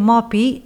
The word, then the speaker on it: mòpia: No deim mòpia ni mòpies, sinó que deim mopi i mopis.